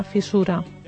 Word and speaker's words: fissura: I una fissura que sovint pronunciem incorrectament, i ens escapa una sonora aquí que no hi va, perquè fissura va amb doble S, i llavors hem de pronunciar fissura, sorda.